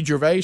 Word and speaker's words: Gervais: Gervais. – Right. Is that right? – Ricky Gervais.